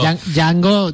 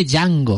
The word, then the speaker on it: Django: y le dice cómo se escribe Django. O sea, Django le dice a Django cómo se escribe y cómo se pronuncia. Que es como... – La D es muda, la D es muda.